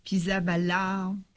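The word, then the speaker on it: Pizzaballa: On avait parlé tout à l'heure de quelques extraits de textes du cardinal Pisaballa, patriarche latin sur Jérusalem.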